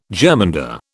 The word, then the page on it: germander: British English